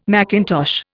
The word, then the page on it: macintosh: Indian